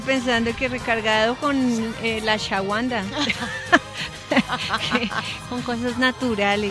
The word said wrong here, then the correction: ashwagandha: A ver, repitan conmigo, niñas. – No, yo dije, ya mira, ashwagandha. No. – Así no se dice. – No, la de Akawanda, acuérdate. Así, la de Wakanda. – La Wakanda forever. – Repitan conmigo, niñas. – Señor. A ver. – Ashwagandha. – Ay, lo dije bien, ashwagandha.